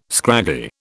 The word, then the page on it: scraggy: British English